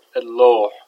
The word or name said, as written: alloo7